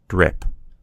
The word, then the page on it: drip: American English